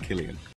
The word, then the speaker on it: Killian: It's an Irish name. It's an old Irish name. I think, like most Irish names, they were saints originally, and it's pronounced Killian.